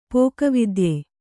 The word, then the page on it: ಪಕ್ಕವಾದ್ಯ: pakka vādya